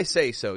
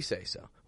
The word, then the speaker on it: says: When we put an S after that, we don't pronounce it says, S-A-Y-S. I don't actually say it like says. I say says. Says, as if it's an S-E-Z, or Z, Z or Z, Z, S-E-Z, says, says. This is American English, so we're going to stick to Z, S-E-Z.